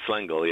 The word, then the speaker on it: Lengel: I believe it's Lengel, yeah. I believe that's the way he pronounced it. Sometimes we would say Lengel, but it was Ed Lengel.